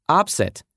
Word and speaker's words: upset: И соглашусь, на слух это слово можно было бы и не узнать, гораздо привычнее его слышать в другом произношении, как глагол upset — это огорчать, беспокоить, волновать, нарушать.